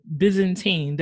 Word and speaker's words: Byzantine: I saw the Byzantine. Did I pronounce that correctly? – Yeah, yep, you got it.